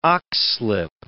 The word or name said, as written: oxlip